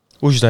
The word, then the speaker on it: Oujda: Marrakesh, Agadir en Oujda. Zeg ik dat goed? – Oujda, ja. ja.